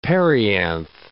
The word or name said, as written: perianth